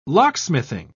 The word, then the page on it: locksmithing: British English